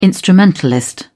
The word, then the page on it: instrumentalist: British English